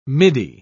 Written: midi

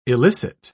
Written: illicit

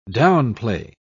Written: downplay

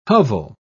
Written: hovel

